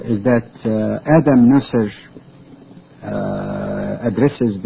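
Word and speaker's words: Neuser: It's N-E-U-S-E-R, Nusser, that's how I thought it should pronounce.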